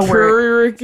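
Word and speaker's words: kyriarchy: It's K-Y-R, curiarchy. It's like. Just a curiarchy? – Word.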